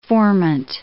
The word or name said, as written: formant